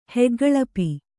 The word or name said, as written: ಹೆಗ್ಗಳಪಿ